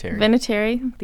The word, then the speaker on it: Vinatieri: Vinatieri.